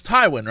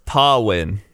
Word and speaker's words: Tywyn: It's Tywyn, right? Yep. Or it's Tawin.